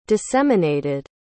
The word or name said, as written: disseminated